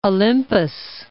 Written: Mount Olympus

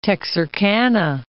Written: Texarkana

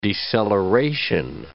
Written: deceleration

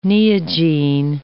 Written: Neogene

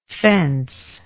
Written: fencelike

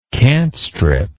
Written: cant strip

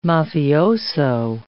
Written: mafioso

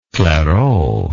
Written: clairaut

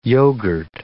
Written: yoghurt

